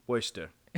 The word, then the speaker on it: Worcester: Worcester. Skip the letters, yeah.